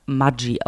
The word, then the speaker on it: Maggi: Was ja übrigens Ach. Mudgy ausgesprochen wird, wenn man es wirklich sagen möchte.